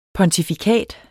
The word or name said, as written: pontifikat